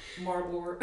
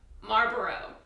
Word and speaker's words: Marlboro: Marlboro. – Wait, can you Marlboro. Because there's an L. Do you pronounce the L? – Marlboro.